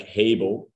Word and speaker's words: Hable: And if we pronounce it like Hable, it has able in there because we enable people to use technology.